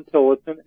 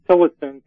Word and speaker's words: Tillotson: You can just type my name in if you spell it properly, Alan Tillotson, A-L-A-N, Tillotson, T-I-L-L-O-T-S-O-N.